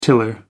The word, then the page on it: tiller: American English